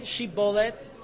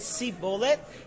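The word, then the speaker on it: shibboleth: They'd say, "Okay, say Shibolet." And the people of Ephraim, they couldn't pronounce a shin. They'd say Sibolet.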